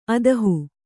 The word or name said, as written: ಅದುಹು